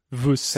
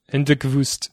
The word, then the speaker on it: Wüst: Wüst. – Hendrik Wüst? – Hendrik Wüst.